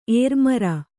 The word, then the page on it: ಏರುಮಾರು: ērumāru; kannada